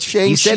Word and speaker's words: Shang-Chi: He said Shang-Chi. – He said she, not chi.